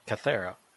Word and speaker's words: Kythera: Kythera.